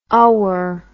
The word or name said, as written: ower